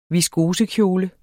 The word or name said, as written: viskosekjole